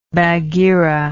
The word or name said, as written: bagheera